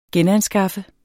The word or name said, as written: genanskaffe